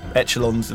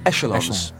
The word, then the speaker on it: echelons: And good word mispronounced, but yeah, good word. – Yeah, well, go on, then pronounce it right then. – Echelons. – Echelon. Oh, right.